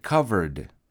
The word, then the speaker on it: covered: But remember the way we pronounce it, covered, covered. It has an ED at the end, right? It's spelled C-O-V-E-R-E-D, but we pronounce it covered.